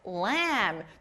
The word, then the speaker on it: lamb: 친구들 여기 끝에 b 소리는 안 나요. 그래서 오늘 배운 첫 번째 단어 매 하고 오는 아기 양 영어로 뭐였죠? – lamb. – Correct. lamb.